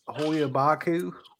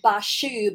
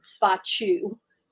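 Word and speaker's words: Hoia Baciu: Well, it's in American, it's Bashu, but in Romania, it's Bachu. So I went and looked it up before we got started, so I'd make sure I pronounced it properly.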